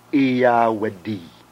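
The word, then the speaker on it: Iyawaddy (ဧရာဝတီ): The river Irrawaddy in Burmese is pronounced Iyawaddy.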